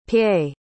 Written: paye

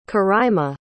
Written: coraima

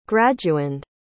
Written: graduand

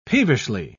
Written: peevishly

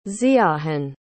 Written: ziahon

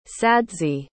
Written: sadzi

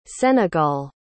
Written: senegal

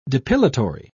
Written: depilatory

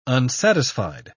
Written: unsatisfied

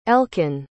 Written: elkan

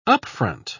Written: upfront

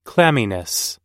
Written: clamminess

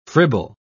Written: fribble

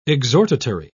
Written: exhortatory

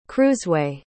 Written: cruiseway

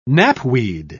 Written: knapweed

